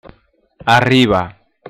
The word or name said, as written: arriba